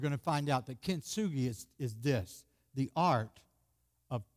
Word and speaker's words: Kintsugi: But Kent, K-I-N-T, Sugi, Kintsugi. Can you say that? Kintsugi. See the word? You try to pronounce it.